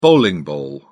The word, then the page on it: bowling ball: British English